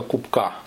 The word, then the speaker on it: Кубка: Приток реки Колыма, река Большая Кубка, ударение на последнюю А, на последнюю гласную.